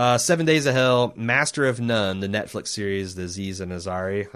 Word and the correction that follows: Aziz Ansari: Is that how you pronounce his name? – Aziz Ansari, yeah.